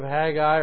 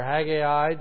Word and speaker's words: Haggai: If you don't know where the book of Haggai or Haggai, depending on how you say it,